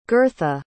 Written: girtha

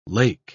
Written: lake

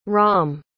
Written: rom

